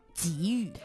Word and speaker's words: 给予: 这个时候呢，它就读作三声，正确的读音是给予。